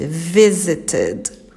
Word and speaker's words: visited: Então, no caso de visit no passado, a pronúncia é o ED falado normalmente. Visited.